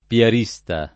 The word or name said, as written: piarista